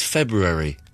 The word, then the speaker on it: February: Incorrect. It's February. There's an R there.